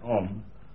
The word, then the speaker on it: Om: You'll notice that in this Sanskrit Om, it is frequently written A-U-M in order to pronounce it. Now, it is pronounced from the back of the mouth to the front of the mouth through the middle of the mouth.